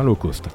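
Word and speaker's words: L'Eau Cost: Alors Low Cost, pour nos auditeurs, je précise que c'est L' e comme l'eau, hein, et cost comme le, le coût en anglais, hein, c'est bien ça. Hein. – Tout à fait. – Et ça se prononce bien low cost.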